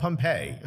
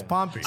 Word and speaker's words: Pompey: Which I've always called Pompeii. I realized that is wrong. – But that's your problem. – No, it's on me. Yeah. It was thank you to Ellie Benjamin that I learned how to say it. – Right, okay. – It's Pompey.